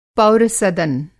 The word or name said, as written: पौरसदन